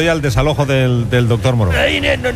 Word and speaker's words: Moreau: ¡Se pronuncia Moreau! ¡Doctor Moreau!